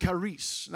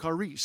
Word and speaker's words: charis: The Greek word to have grace, this Greek word that's translated to have grace, is the word charis. Now, I may be a little off in my pronunciation, but charis, C-H-A-R-I-S, charis.